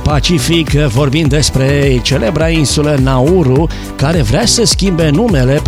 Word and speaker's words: Nauru: Nauru, pronunțat în mod obișnuit Nauru, a devenit numele oficial al insulei, deoarece numele său indigen nu putea fi pronunțat corect de limbile străine.